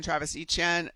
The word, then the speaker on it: Travis Etienne: Seattle Seahawks linebacker Ernest Jones at 97, Travis Etienne, that's how you say it. That's how he corrected all of us.